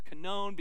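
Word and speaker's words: kanon (κανών): Some people say kanon, but it's kanon because that little W in the Greek actually pronounces as a hard O.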